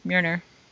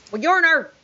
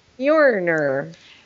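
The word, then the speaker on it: Mjölnir: Mjolnir! – You gave it an extra syllable. – Mjolnir, no. – Mjolnir.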